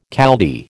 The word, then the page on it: Chaldee: American English